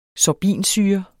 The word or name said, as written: sorbinsyre